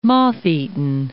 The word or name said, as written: moth-eaten